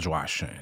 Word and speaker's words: Joachim: Well, my actual name is pronounced Tanael Joachim.